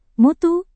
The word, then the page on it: mutu: Indonesian